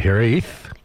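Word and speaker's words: Hiraeth: Well, I can tell you that Hereth. – Yes. – Is the way to pronounce it. Hereth.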